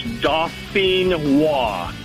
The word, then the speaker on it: Dauphinois: It's, like I said, it's French. It's Dauphinois. – Dauphinois. – And Dauphinois.